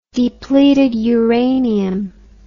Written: depleted uranium

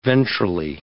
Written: ventrally

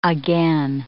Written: again

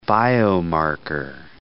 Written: biomarker

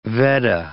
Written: Vedda

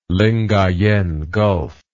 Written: Lingayen Gulf

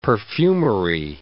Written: perfumery

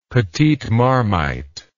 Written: petite marmite

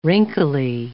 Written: wrinkly

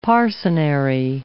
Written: parcenary

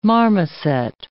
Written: marmoset

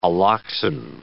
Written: alloxan

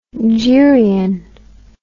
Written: geryon

